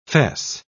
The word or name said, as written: fess